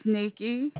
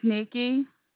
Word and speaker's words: Nikki: It's Nikki. – I'm sorry, what is your name? – Yes, ma'am. It's Nikki.